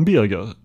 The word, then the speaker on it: Birger: Jag noterar att du uttalar Birger med hårt G.